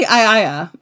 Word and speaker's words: Aeaea: So this island is spelled A-E-A-E-A. – Well, it's actually Aea is how it's pronounced, Aea.